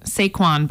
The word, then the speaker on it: Saquon: All right, last week we talked about Saquon Barkley. – Look at you. You even got pronunciations mastered this week.